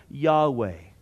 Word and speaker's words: Yahweh: and usually it's pronounced as Yahweh. Now, whenever you see little L-O-R-D, that word in the Hebrew is the word Adonai.